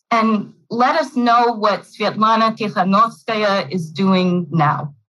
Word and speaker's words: Svetlana Tikhanovskaya: Basically, Lukashenko jails one political opponent only to have him replaced by the opponent's wife, Svetlana Tikhanovskaya. Did I get that pronunciation correct? – Absolutely.